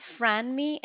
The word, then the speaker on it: Franmi: Yes, it's Franmi. It's F as in Frank, R-A-N-M-I.